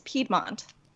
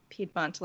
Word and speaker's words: Piedmont: Oh, it's Piedmont. – I thought so, but I wasn't sure if it was Piedmont. – It's spelled Piedmont, but it's like a Piedmont, like the region in Italy.